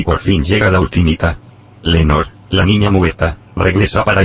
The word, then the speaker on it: Lenore: La tercera novedad está aquí, yo no sé si se pronuncia Lenor o Lenore. Collejas. – No lo sé yo tampoco. – Yo creo que es Lenor, sí. – Sí, es Lenor.